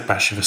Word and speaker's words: Bashevis: Isaac Bashevis Singer, am I pronouncing Bashevis right? – Yes.